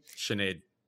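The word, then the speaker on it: Sinéad: You can see her cage 40 bout, but also worth keeping a note, she fought Sinhead Kavanaugh, right? – Sinead. – That's how you say it over there. Yeah.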